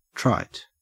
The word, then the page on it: trite: Australian English